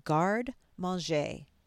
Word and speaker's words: Garde Manger: It's spelled G-A-R-D-E and then M-A-N-G-E-R, and it's pronounced Garde Manger.